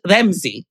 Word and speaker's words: Ramzi: I think that they usually say Ramsey, but it would be pronounced in the Arabic, it would be pronounced Remzi. – Right. – Right. Like right. It's, right. So it's almost like R-E-M-Z-I rather than R-A-M-Z-I.